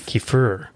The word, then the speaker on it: kefir: Was it Kofif? Is that what you were going to say? Kofif? – How did you know this? Okay. What? No. Kefir.